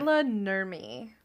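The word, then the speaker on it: Maila Nurmi: Myla Nurmi, I believe, is how it's pronounced.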